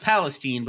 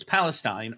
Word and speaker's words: Palestine: Palestine, it's pronounced Palestine, but it's Palestine, Ohio.